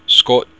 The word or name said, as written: scot